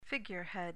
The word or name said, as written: figurehead